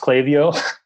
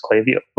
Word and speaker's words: Klaviyo: It is pronounced Klaviyo. I don't know why I always... – No, I don't know if it's right or not. I'm going to the conference. – No, it is. I always say Klaviyo. I learned about it while I was in Colombia, so everything's in Spanish. So I just pronounce it as it is in Spanish. So, but then I talked with Klaviyo and I watched some of their videos and they're like, I'm like, oh, it is Klaviyo.